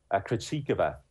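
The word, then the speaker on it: Krejčíková: The correct pronunciation of her surname? – Krejcikova. Barbora Krejcikova.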